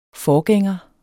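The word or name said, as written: FORGÆNGERE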